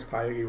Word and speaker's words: piety: I figured out why I pronounce piety wrong. – Why? – Because I pronounce it like deity.